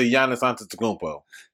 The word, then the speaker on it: Giannis Antetokounmpo: Now, how the fuck you just not getting to Giannis Antetokounmpo? That's how you say his name.